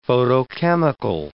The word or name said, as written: photochemical